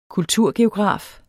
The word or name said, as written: kulturgeograf